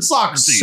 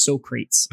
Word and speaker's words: Socrates: You're like a modern Socrates. – Sir, it's pronounced Socrates. – Socrates, my bad.